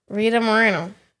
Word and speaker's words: Rita Moreno: It's Rita Moreno, right? – Rita Moreno.